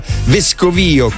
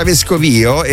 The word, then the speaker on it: Vescovio: Io lo sapevo che se avessi pronunciato Piazza Vescovio così, con l'accento sulla I,